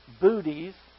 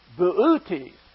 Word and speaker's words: Boötes: This isn't pronounced booties or boats or boots. It's Boötes. Boötes. Here he is right here.